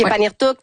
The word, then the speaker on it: Paniirtuk: Elle fait ses capsules en direct de Paniirtuk. Est-ce que c'est comme ça qu'on prononce? – Oui, oui. – OK, Paniirtuk.